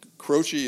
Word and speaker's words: Croce: Croce is the way it's spelt, but it's Croce, which is kind of interesting.